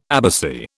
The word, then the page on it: abbacy: British English